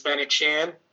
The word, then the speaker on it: Fennec Shan: So, um, you know, she returns as Fennec Shan.